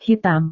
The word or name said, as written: hitam